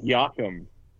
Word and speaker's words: Joachim: I believe it's Joachim Anderson. – Is it? – Yeah. There we go, Joachim. Yeah. There you okay. – Go. – Yeah, because that's Danish.